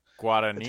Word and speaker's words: Guadagnino: It's Luca Guadagnino.